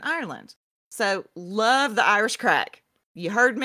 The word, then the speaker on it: craic: To their way of thinking, this was just another example of Irish crack. And that is C-R-A-I-C. And I looked up the definition and the pronunciation, Irish listeners, I swear. And it said crack.